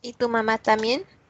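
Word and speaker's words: Y Tu Mamá También: But Y Tu Mama Tambien, has any of you seen that movie? – Y Tu Mama Tambien? – Yeah, there you go, that.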